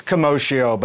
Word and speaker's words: commotio: Yeah, it's commotio, but you're right. People do often say both. Commotio is the correct pronunciation.